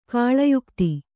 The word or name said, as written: ಕಾಳಯುಕ್ತಿ